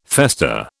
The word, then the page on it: fester: British English